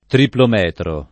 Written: triplometro

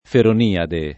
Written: Feroniade

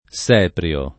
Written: Seprio

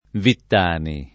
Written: Vittani

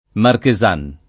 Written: marchesana

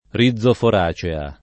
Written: rizoforacea

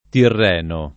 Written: tirreno

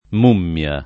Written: mummia

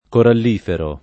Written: corallifero

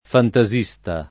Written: fantasista

